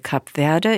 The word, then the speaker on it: Kap Verde: Die Aussprache von Kap Verde ist so: Kap Verde.